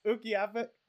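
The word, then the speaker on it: Utqiagvik: You don't remember how to pronounce that town? – Ukiavik. I was right.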